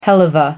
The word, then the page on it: helluva: American English